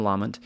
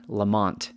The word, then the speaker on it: Lamont: And maybe here in America, that's how a lot of us pronounce it anyway. And so my understanding, based off of the little helpful audio hint they gave on the Wikipedia page, it's Lamont.